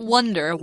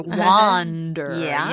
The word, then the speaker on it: wander: Not wonder, wander. yeah, so we have to make sure we're pronouncing it right. With a ah, not a ah, with a, not a uh.